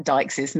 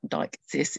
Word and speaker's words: deixis: And I think I did start to mention last time her use of this linguistic concept of deixis, which I should point out is spelled D-E-I-X-I-S. So it's that deixis, not deixis, it's deixis.